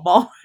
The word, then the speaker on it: Maul: Maul rhymes with Paul.